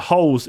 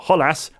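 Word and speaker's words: Holeš: the Czech Republic could really exploit the holes in the Dutch defence. And speaking of holes, it was a man called Holes, or Holas, as it's pronounced, who did the damage.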